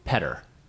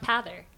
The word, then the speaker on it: Petter: Petter. – It's not Pather. – It's Peter with an extra T. – Right. – It sounds like the Irish name Pather.